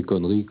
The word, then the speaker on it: Connery: On prononce Sean à l'irlandaise et Connery comme en français.